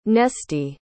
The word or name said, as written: Nesty